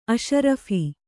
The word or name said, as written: ಅಷರಫಿ಼